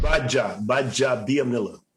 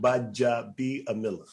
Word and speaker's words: Gbaja-Biamila: Gbaja-Biamila. – Oh, man. Why don't you get a normal name? – The G is silent. So if you take the D out, Gbaja-Biamila.